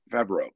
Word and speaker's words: Favreau: If you either like the sort of Woody Allen inflected Jon Favreau comedy, I can never say his name properly. – Favreau, thank you.